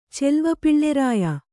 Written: ಚೆಲ್ವಪಿಳ್ಳೆರಾಯ

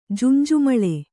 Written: ಜುಂಜುಮಳೆ

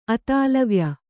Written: ಅತಾಲವ್ಯ

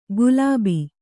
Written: ಗುಲಾಬಿ

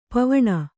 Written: ಪಾವುಣಿ

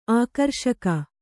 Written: ಆಕರ್ಷಕ

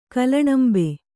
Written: ಕಲ್ಲಣಂಬೆ

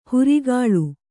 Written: ಹಿರಿಕೊಳ್

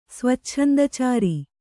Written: ಸ್ವಚ್ಛಂದಚಾರಿ

